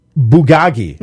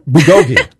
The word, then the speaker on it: bulgogi: Bulgogi. – Nope. – Bulgogi.